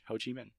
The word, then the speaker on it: Ho Chi Minh: Ho Chi Minh City. – Are you pronouncing it right? – Yeah. H O space C H I space M I N H. Ho Chi Minh.